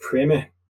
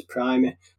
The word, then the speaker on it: primer: Basically, in American English, it seems to be pronounced Primer. – Uh-huh. – So perhaps that's why, but in British English, it's always going to be referred to as Primer.